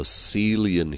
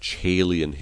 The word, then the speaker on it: Caelian: It was called the Monastery of St. Andrew on the Caelian Hill. Sometimes that's pronounced Caelian Hill.